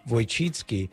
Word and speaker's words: Wojcicki: It's pronounced Wojcicki. It was a joke, Susan. I know Polish when I hear it, Wojcicki, even though I couldn't pronounce it quite so well.